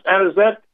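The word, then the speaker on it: Anisette: You like my accent, the way I pronounce Anizet? – You said it perfectly.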